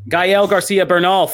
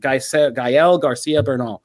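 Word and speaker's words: Gael García Bernal: Gael Garcia Bernal. Thank you, thank you. I was just butchering his name. I'm sorry, Gael. I'm so sorry, but Gael Garcia Bernal.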